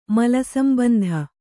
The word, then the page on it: ಮಲಸಂಬಂಧ: Kannada